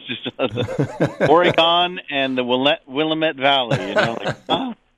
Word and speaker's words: Willamette: It's nice to hear Willamette pronounced the right way.